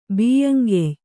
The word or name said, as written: ಬಿಯ್ಯಂಗೆಯ್